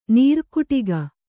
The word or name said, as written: ನೀರುಕುಟಿಗ